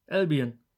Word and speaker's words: Albion: Albion, so wie es da steht.